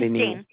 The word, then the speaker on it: Jane: Oh, my name is Jane. I'm sorry. – Yeah, Jane. Can you spell it for me? – J-A-N-E. – A-N-E.